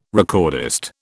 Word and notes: recordist: British English

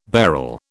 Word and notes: bharal: British English

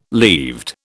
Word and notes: leaved: British English

